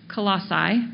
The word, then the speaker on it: Colossae: Colossae is how you pronounce it, if you'd like to know.